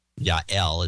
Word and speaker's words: Yael: Yael is his name. That's how you pronounce that. Y-A-E-L.